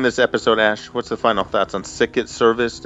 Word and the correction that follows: Sicut Servus: It's pronounced Sicut Service.